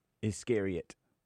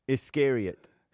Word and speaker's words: Iscariot: What did Judas Iscariot, how you say it? – Iscariot. – What? – Iscariot.